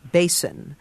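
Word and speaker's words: besan: basin. It's B-E-S-A-N, but you pronounce it like a wash basin.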